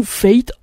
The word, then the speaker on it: F8: donc qui sortait l'album F8 ou F8 ou Fate, vous le prononcez comme vous voulez, en tout cas ça s'écrit F8.